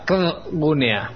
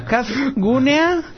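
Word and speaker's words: KZgunea: KZ. ¿Y eso cómo se pronuncia? – KZ Gunia. – Son dos consonantes, no se puede pronunciar. La Z. – Ah, ¿cómo que no? Espera, Kazgunea.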